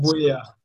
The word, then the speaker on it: Buea: So did you pronounce it Buea? – Yes, it's pronounced Buea. Yeah. – Buea. – That's how it's pronounced.